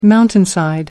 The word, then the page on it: mountainside: American English